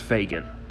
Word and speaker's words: Fagin: Also, if you ever do read Oliver Twist, don't giggle at the character whose name is spelled F-A-G-I-N. It's pronounced Fagin.